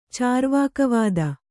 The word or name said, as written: ಚಾರ್ವಾಕವಾದ